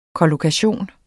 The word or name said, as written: kollokation